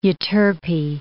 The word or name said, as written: Euterpe